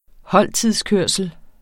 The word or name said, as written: holdtidskørsel